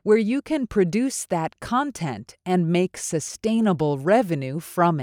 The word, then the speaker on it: content: This word could be a noun, in which case it's pronounced as content. It can also be an adjective, but then you say it like this, content. So in this sentence, because it's being used as a noun, you want to emphasize the first syllable.